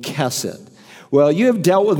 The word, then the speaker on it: chesed: People mispronounce it all the time. It's not hesed, it's kesed. Say that, kesed.